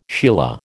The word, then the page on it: sheila: American English